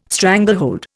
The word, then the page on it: stranglehold: Indian